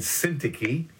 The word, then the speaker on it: Syntyche: Two women by the name of Euodias and Syntyche. That's how you pronounce it, Philippians 4.